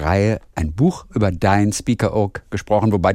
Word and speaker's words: Spiekeroog: Muss man das mit einem Spitzen aussprechen? Nee, Spiekeroog eigentlich. – Ich mach's nicht. – Guck mal, Spiekeroog.